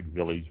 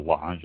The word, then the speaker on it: Village: Below that is the Village, or it looks like Village, but Village, I believe it's pronounced.